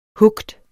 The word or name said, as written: hooked